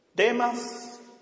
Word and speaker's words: Demas: Demas, con acento en la A, me ha abandonado. Demas me ha abandonado, amando este mundo, y se fue a Tesalónica.